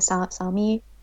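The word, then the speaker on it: Sámi: Well, isn't it, reindeer is mostly the Sam, I feel I'm going to sound really ignorant, the Sami people, the Sami, the indigenous. – Yeah, I don't know how it's pronounced in English, but it's Sami yeah.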